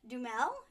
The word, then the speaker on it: Duhamel: who's played by Josh Duhamel.